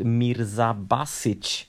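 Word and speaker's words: Mirza Bašić: Att eh, han eh, senast förlorade mot Mirza Basic i Marrakesh. – Bra uttal, är ju bra uttal.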